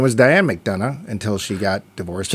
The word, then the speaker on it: McDonagh: I think it's just McDonagh, but there you go. – Yeah, it's a Celtic last name, so sometimes you pronounce the last two.